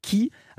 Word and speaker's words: Kee: Nouveauté, on vient de l'entendre, c'est ce single DNA avec Ki. Alors, si j'ose dire, qui est qui ? – Qui est qui ? – Ça s'écrit K-E-E. – K-E-E,